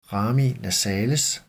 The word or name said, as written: rami nasales